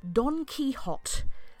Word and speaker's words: Don Quixote: He told me it is pronounced Don Quixote, like a hot donkey, Don Quixote, Donquixote.